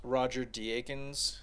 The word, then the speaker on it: Deakins: But another one is Roger Deakins, mm-hmm.